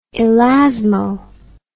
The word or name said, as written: elasmo